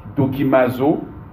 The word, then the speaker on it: dokimazo: C'est le mot dikomazo. Dikomazo qui veut dire mettre à l'épreuve, examiner, éprouver. Pour ceux qui écrivent dikomazo, c'est écrit comme ça se prononce. Dokimazo. Donc c'est écrit D-O-K-I-M-A-Z-O. D-O-K-I-M-A-Z-O.